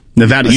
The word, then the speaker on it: Nevada: Nevada, you said it right. – I always say it right.